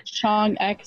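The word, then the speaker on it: Choeung Ek: Chong Ek, maybe? – Chong Ek, Choeung space Ek.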